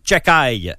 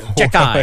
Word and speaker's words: Xhekaj: Et le défenseur Arber Chekaj. C'est un nom difficile à prononcer. Le X-H-E-K-A-J.